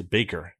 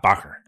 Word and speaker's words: Bacher: It's Pierce Baker, not Bacher. – Okay. – It's very tempting to say Bacher because it's spelled B-A-C-H-E-R.